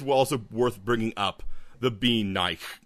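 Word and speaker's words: bean nighe: It's spelled Bean, B-E-A-N. – Yeah. – And then Nye, as in like N-I-G-H-E. – Oh, yeah. – Bean Nye, or something like that.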